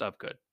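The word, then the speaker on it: Ufgood: Right, it's U-F-G-O-O-D, but it's pronounced Ofgood.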